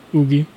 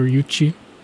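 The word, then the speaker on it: Yuqi: G-Idle, my favorite member is probably Oogie or Yuchi, depending on how you want to pronounce it. She is Chinese, but the way they pronounce it in Korean is Oogie.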